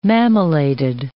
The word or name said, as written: mammillated